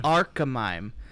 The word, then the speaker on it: Archimime: Am I saying the name right? – Archimime. – Yes. Archimime.